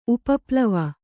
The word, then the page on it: ಉಪಪ್ಲವ: upaplava